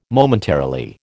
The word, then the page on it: Momentarily: American English